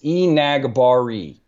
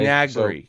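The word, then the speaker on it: Enagbare: No, according to, I've heard like five different pronunciations, but according to the South Carolina pronunciation guide that I have in front of me right now, it's Enagbury. – Okay? Enagbury.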